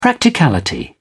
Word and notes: practicality: British English

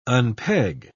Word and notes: unpeg: British English

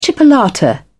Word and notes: chipolata: British English

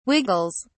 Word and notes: wiggles: British English

